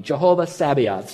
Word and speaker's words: Jehovah Sabaoth: Jehovah Sabaoth.